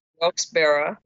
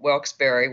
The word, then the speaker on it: Wilkes-Barre: Wilkes-Barre, like Yogi Berra, Wilkes-Barre, which is what I eventually said, and Wilkes-Barre, which they said, Joe Biden said that, and he's from Delaware, so he should know.